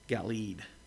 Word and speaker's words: Galeed: But Jacob did much better, and he called it Galeed, G-A-L-E-E-D. So that's why we stick with his pronunciation.